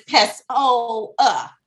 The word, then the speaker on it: Pessoa: But it rhymes with ho, like think hoa. – Yeah, like, oh. I was like, I'm not a ho, but my name is Pessoa.